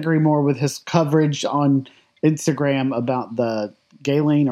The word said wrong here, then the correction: Ghislaine: or however you say her name. I can never say it right. – Oh, Ghislaine. – Yes, thank you.